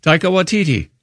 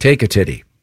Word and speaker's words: Taika Waititi: Pronounce it again. Taika. – Taika Waititi. – Okay, I thought it was like take a titty.